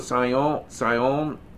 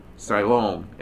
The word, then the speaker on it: Siloam: And he anointed the eyes of the blind man, and he went and washed in the pool of Siloam. Am I pronouncing that right? – No, Siloam. – Siloam. Oh, there we go.